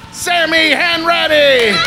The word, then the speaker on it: Sammy Hanratty: From Shameless, Shammy Hanratty! – Sammy! – Let's do that one again, because I said it wrong. From Shameless, Sammy Hanratty! So sorry I called you Shammy Hanratty.